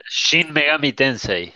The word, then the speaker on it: Shin Megami Tensei: Y la rabia me opacó todo habían cosas buenas como Shinigami, no sé cómo se pronuncia, cómo era que se decía. – Shin Megami Tensei.